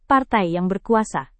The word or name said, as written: partai yang berkuasa